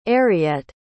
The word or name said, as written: ariette